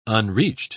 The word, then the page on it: unreached: British English